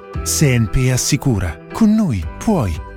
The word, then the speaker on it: CNP: Si legge Senpi, si scrive CNP.